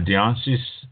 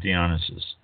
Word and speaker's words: Dionysus: Dionysus, D-I-O-N-Y-S-U-S, from which, incidentally, the name Dionysus comes, was well known throughout the ancient world. In 2002, archaeological excavations unearthed the sacred sanctuary of Dionysus in the complex known as Perpinkon in the east, rolled-up mountains of modern-day Bulgaria.